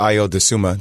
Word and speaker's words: Ayo Dosunmu: I thought Ayo Dosunmu, that's how you say his name. Trust me, I butchered it for a very long time.